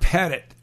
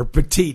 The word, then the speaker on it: Petit: Yusmeiro Pettit, or Petit is the way it's pronounced, has pitched great for you.